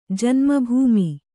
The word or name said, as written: ಜನ್ಮ ಭೂಮಿ